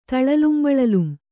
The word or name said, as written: ತಳಲುಂಬಳಲುಂ